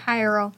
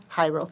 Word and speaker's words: Jairo: how do you pronounce this? Because I think it's a Spanish name. Is it Jairo? – Jairo. – Jairo, thank you.